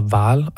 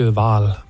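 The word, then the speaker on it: Hvaal: Og jeg kan godt forstå, at du synger lidt på A'et der, fordi det er H-V-A-A-L-L. – Ja. – Ja, så hvordan udtaler man det? Men jeg tror, du gjorde det meget godt. – Jamen, jeg går lidt nordisk Val på den. det er mit bedste bud.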